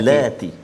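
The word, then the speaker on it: allati (اللاتي): cara bacanya apa ustaz ya uh, al dua harakat dua harakat ya ah, allati oh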